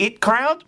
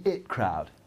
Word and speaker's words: The IT Crowd: I always used to call it the IT crowd, but I saw a behind-the-scenes clip with the producer where he pronounced it as thus.